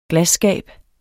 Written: glasskab

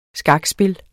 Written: skakspil

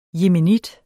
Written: yemenit

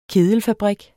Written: kedelfabrik

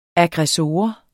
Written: aggressor

